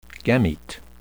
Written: gamete

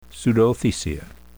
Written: pseudothecia

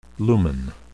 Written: lumen

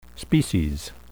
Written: species

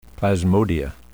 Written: plasmodia

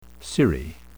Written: cirrhi

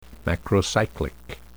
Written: macrocyclic

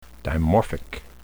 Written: dimorphic

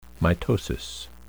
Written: mitosis

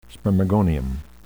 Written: spermagonium

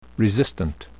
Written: resistant

